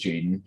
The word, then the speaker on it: JXDN: So I was talking to myself or to our audience, but my number two pick, and I pronounced it Jaden, JXDN, right? – Jaxton. – Jaxton? – Yes. – Huh? – Jax? I don't know. I think you're right. It is Jaden, but it is.